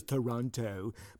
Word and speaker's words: Toronto: And, of course, nobody from outside of Canada says Toronto the same way we do. They pronounce it perfectly, and we don't in Canada mostly, you know. Like, I mean, it actually is Toronto, but we don't say it that way.